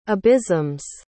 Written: abysms

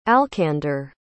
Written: alcander

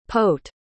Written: pote

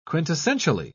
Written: quintessentially

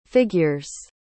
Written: figures

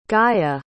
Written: gaya

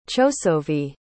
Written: chosovi

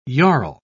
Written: jarl

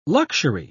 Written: luxury

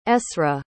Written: esra

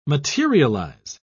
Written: materialize

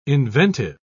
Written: inventive

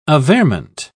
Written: averment

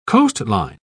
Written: coastline

